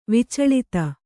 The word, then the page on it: ವಿಚಳಿತೆ: vicaḷite